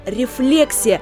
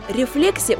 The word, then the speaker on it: рефлексия: Друзья, запомните правильное произношение – рефлексия. Чтобы больше не путаться, вспоминайте слово «рефлекс», и вы сразу же поймете, что в слове «рефлексия» ударение на второй слог.